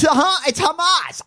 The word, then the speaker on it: Hamas: It's Hamas.